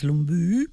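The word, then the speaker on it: Lumbye: I went and found out there's a wonderful lady here who knows everything called Nola, and she told me to pronounce it as Lumbu.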